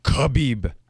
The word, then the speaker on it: Khabib: Khabib.